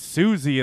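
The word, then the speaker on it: Siouxsie: And she's like, no, it's pronounced Susie and the Banshees.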